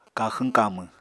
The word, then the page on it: kaxwñ-kamwñ: Apatani